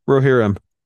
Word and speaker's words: Rohirrim: Rohirrim. – Okay, yeah, that one. War of the Rohirrim.